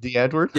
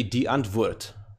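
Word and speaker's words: Die Antwoord: It's actually an Afrikaans word, so it's actually D. Antwoord. So it's more, you must try and say it with a German.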